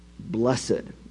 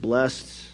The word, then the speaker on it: blessed: They don't tell us how to be happy, but how to be blessed. Some people pronounce it blessed, others pronounce it blessed, the old way to say it. I just grew up hearing it that way.